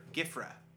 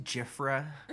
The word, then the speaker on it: Gifra: Gifra, like she's got a gift for you, baby. – And it's not pronounced Jifra.